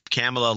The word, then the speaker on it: Kamala: She pronounces it Kamala. Okay, well, Kamala like Pamela.